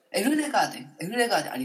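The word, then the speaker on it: ELLEGARDEN: エルレガーデン、ありがとうございます。エルレガーデンピッツァマン、これは私が間違えた、ピザカット 5、これいいね。ピザカット 5もなかなかノリいいタイトルなんで、私たちバンド組むとしたらピザカット5 でいきましょうっていうふうに意気投合して、この曲になりました。エルレガーデンピザマンです。トムさん、ミュージックトゥ、プリーズ、コー。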